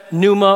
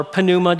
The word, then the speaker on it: pneuma: And then in the New Testament, pneuma or pneuma, depending on how you want to pronounce it, that word pneuma is the word for spirit or breath or wind.